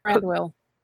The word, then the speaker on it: Thranduil: Is that how you say his name? Thranduil? – Thranduil.